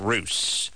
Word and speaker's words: Russ: Well, he spells it R-U-S-S, but it's pronounced Roos.